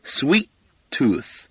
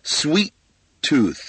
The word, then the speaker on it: sweet tooth: 最后的 th 也咬舌头 tooth 啊，两个 t 在一起的时候，第一个 t 就不发音了 sweet tooth, sweet tooth.